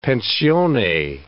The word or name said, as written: pensione